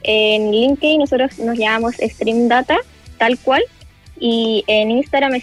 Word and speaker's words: Stream Data: Se pronuncia Stream Data, sí.